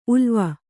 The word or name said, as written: ಉಲಿವು